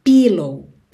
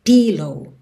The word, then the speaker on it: pillow: Se escreve com I, mas não é esse I, pillow. Pillow, tá? É pillow, pillow.